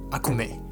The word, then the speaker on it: ACME: 没有没有关系，但是他们的念法就不一样，他们是念 Akume。